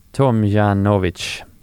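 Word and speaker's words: Tomljanović: Sverigetan förlorade i två raka set mot Ajla Tomjanovic.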